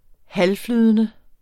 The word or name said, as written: halvflydende